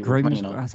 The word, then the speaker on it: Grimsby: You have Southampton versus Grimsbury, Burnley versus Fleetwood. – Grimsby. How did you say it? Grimsby.